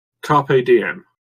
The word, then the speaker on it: carpe diem: Carpe Diem.